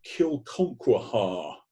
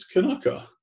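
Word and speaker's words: Kilconquhar: And you go, "How do I pronounce this? Kilconquaha." But no, it's not. It's Kanaka.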